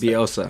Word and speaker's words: Bielsa: Man, I can never pronounce Bielsa. – That. Bielsa. There we go.